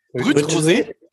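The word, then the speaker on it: Brut Rosé: Wir haben ja einen Sekt, ein Brü-Rosé, richtig ausgesprochen? – Brüt-Rosé.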